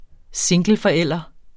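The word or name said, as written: singleforældre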